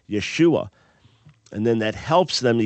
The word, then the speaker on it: Yeshua: Now, some would argue that Yeshu was the proper pronunciation. We would argue that it's Yeshua, but some argue that that final dropped off at a certain point, hence Yeshu.